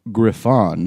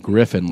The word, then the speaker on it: Griffon: He is 50% Brussels Griffon. – That's how you say it. – Not Griffin, like some fucking piece of white trash.